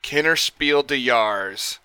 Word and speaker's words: Kennerspiel des Jahres: but this game was nominated for the 2023 Kennerspiel des Jahres.